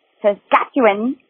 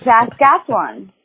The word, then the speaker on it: Saskatchewan: So you said it like Saskatchewan, and it's Saskatchewan. So you're almost there.